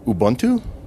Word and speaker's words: Ubuntu: but then I'm going to jump to the last song, which is Ubuntu.